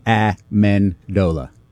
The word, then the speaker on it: Amendola: How do you pronounce Danny's name? – Amendola. – Amendola, I thought.